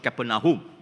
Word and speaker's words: Capernaum: Actually, we should pronounce it Capernaum, because Capernaum means the house of Nahum. Caper means house. Capernaum, not Capernaum. Capernaum.